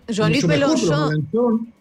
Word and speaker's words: Jean-Luc Mélenchon: Jean-Luc Mélenchon.